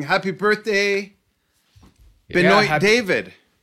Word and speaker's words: Benoit: Yeah, I joke because I was a huge basketball fan back in the day, and there was a player named Benoit Benjamin is how they pronounced his name.